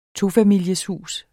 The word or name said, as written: tofamilieshus